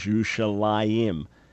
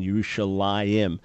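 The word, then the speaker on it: Yerushalayim (ירושלים): But the vocalization, the vowels indicate the pronunciation is Yerushalayim, which is a dual ending, Yerushalayim.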